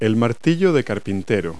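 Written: claw hammer

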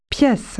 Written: room

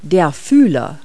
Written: antenna

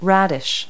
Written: radish

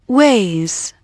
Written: weighs

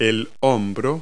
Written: shoulder

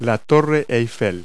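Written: Eiffel Tower

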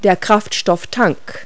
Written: fuel tank

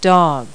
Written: dog

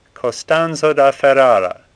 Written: Costanzo

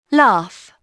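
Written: laugh

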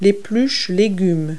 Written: peeler

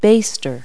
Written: baster

